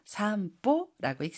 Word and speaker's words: 散歩: 일본어에서는 산보라고 하는 한자를 써가지고 산보라고 읽습니다.